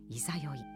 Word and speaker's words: 十六夜: それから満月を過ぎると月の出が遅くなっていくわけですが十五夜の翌日は十六夜と書いて「いざよい」これは「ためらう」という意味の「いさよう」が語源とされています。